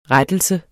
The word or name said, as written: RETTELSEN